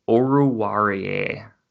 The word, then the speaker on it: Oruwariye: The pick I did really like was in the fifth round, they took cornerback Amani Oruwariye.